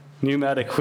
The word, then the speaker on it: pneumatic: pneumatic wheels. Thank you. Silent P got me there.